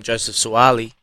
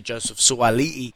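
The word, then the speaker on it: Joseph Suaalii: And I just want to say, yeah, I've been calling him Joseph Suoli'i, and pronunciation is huge for me. And yesterday I learned it was actually Joseph Suoli'i, so I apologise for that as well.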